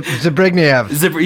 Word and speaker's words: Zbigniew: Zbigniew.